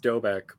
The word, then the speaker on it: Doubek: But so it's pronounced Doubek, but people almost always say Doubek, and I just stopped correcting them forever ago.